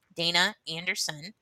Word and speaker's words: Daina Anderson: Daina Anderson.